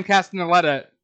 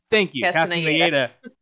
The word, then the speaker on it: Castellaneta: Like, with Dan Castellaneta, I feel like I said that wrong. – Castellaneta voicing Genie.